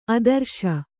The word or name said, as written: ಆದರ್ಶ